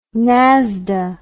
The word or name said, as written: nasda